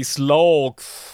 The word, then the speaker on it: Slaugth: Ähm, das sind die Slogth. Slogth? Slogth? Slogth. – Ja, S-L-A-U-G-T-H. Slogth. Ich würde sie als Sloth aussprechen, ähnlich wie das englische Faultier.